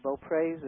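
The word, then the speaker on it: Beauprez: And then there was a governor that was very popular, Owens, and then Beaupres, am I saying it correctly? – Yeah.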